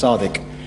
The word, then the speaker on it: Sadiq: So my name is of an Arabic origin. It's in Arabic, it's Suad Alif Dal Kaf, and it's pronounced Sadiq, which the literal translation, you'll love, is truthful.